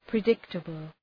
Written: predictable

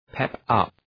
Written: pep up

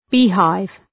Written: beehive

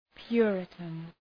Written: puritan

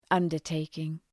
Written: undertaking

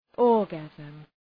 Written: orgasm

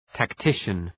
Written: tactician

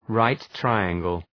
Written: right-triangle

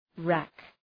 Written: rack

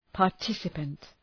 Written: participant

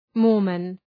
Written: Mormon

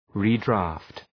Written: redraft

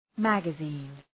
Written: magazine

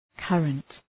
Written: current

